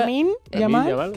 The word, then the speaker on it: Lamine Yamal: Lamine Yamal. – ¿Cómo? – Bueno, claro. Lamine Yamal. Que siempre lo digo mal. – Lo dice mal mucha gente.